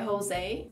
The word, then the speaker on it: Jose: E Jose é José, mas falado com a pronúncia, eu acho que espanhola, que é só para rimar mesmo, assim, no way e o que rima com way é Jose.